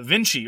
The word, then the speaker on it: Vinci: Like the trade from Vinci or Winky, depending on how you want to pronounce the Latin, to Small World, one of the key changes was that scoring was hidden.